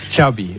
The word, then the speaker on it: Xavi: Xavi.